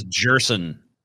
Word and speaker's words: Gerson: Do you pronounce the J in Spanish? A Spanish name would be Erson or Gerson? – Hold on. Where is he at? – His name is Gerson.